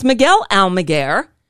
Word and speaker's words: Miguel Almaguer: But my favorite is Miguel Almaguer. – Here we go. – So there's Miguel Almaguer, who says his name just the way I pronounced it.